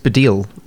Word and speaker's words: Baddiel: His surname's it's Baddiel.